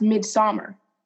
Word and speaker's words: Midsommar: Midsommar? – No, it's pronounced Midsommar. This is Midsummer.